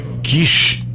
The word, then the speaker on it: Quixelô: Quixelô. Quixelô com... acento. Quixelô. – Quixelô? – Quixelô. Tu viu a pronúncia certa.